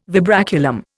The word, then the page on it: vibraculum: Indian